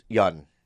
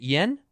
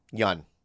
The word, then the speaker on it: Yeun: Yeun. – Because you corrected yourself. Is it Yen? – Yeun. – Yeun. – Yeah. – Y-E-U-N.